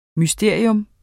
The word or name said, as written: MYSTERIERNE